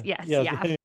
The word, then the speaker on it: YAF: Who are the Young Americans for Freedom, or is they known YAF? Is that how it's pronounced? – Yes. YAF. YAF is a right-wing student group.